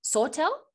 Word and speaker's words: Sawtell: Scotty Schindler is a business and sports leader proudly from, I have never actually pronounced this word before, Sawtell? – You got it.